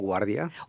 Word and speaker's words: Guardia: We also have Guillermo Gardia. – Guillermo Guardia. – Guardia. Mm-hmm like the airport.